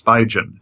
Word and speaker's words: Spigen: There weren't really any good ones, but I think that it's pronounced Spigen, S-P-I-G-E-N is the company.